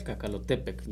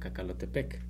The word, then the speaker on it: Cacalotepec: Y bueno, pues el cerro entonces de Cacalotepec, ¿cómo es la pronunciación? – Náhuatl, Cacalotepec, ya españolizado sería Cacalotepec.